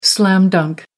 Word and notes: slam dunk: American English

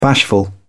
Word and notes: bashful: British English

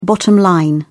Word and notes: bottom line: British English

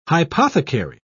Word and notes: hypothecary: British English

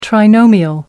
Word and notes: trinomial: British English

